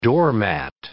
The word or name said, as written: doormat